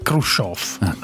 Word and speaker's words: Khrushchev: Comunque è Khrushchev. Adesso c'è Khrushchev. Khrushchev con la E o la O? – Con la io e la E con i due punti sopra. In russo si legge io.